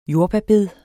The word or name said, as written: jordbærbed